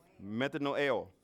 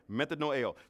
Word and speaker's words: metanoeo: Y'all remember how you pronounce that? We did it last Sunday. Metanoeo. Metanoeo.